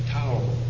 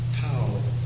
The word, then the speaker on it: tau: Let's go to Tau. It's the letter T in English, but it's pronounced Tau. Some pronounce it Ta, but it doesn't matter.